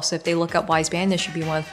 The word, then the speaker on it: Wise Banyan: And I'm going to spell this, by the way, just so if somebody's listening, whether so Wise Banyan, W I S E B A N Y A N. I mean, it's pronounced just like it looks, but Wise Banyan.